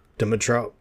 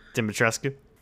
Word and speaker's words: Dimitrescu: EMMIs are absolutely they're worse than Dadgum uh, Lady Dimitro. Oh, fuck. Dimitrescu.